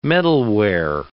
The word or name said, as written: metalware